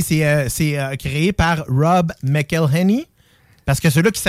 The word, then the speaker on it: Rob McElhenney: c'est Rob McElhenney.